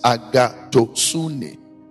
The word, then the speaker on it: agathosune: The Greek word for goodness is agathosune. A-G-A-T-H-O-S-U-N-E. Greek in India. Agathosune. The pronunciation is different from how it is spelled. A-G-A-T-H-O-S-U-N-E.